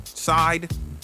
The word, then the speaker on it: Syd: Sid, S-Y-D. Is that how you pronounce that? Side? – Yeah, B.